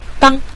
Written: dang5

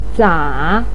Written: za3

